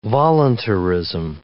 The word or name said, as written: voluntarism